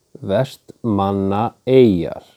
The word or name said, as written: Vestmannaeyjar